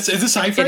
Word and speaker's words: Seyfried: I think this is probably the best thing that happened to Amanda Seyfried, though, because I think she can win Seyfried. The Globe now. Is it Seyfried? – It is Seyfried, yeah.